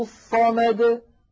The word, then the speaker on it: الصمد: بعض الإخوة يقولون هكذا يا إخوة، قل هو الله أحد، الله الصمد. سمعتم هذا الصوت د؟ إيش هذا د؟ هذا صار حرف ممطوط، ولا تمط العرب هذا الحرف. النطق الصحيح قل هو الله أحد، الله الصمد د، هكذا زمنه.